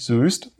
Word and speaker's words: Soest: Und wir haben über die Aussprache von Soest gerätselt, weil sich Soest ja mit OE schreibt. Lagen aber letztendlich richtig, also es heißt nicht Soest, sondern Soest.